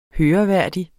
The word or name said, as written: høreværdig